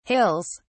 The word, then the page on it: hills: British English